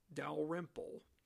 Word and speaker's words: Dalrymple: D-A-L-R-Y-M-P-L. Dalrymple.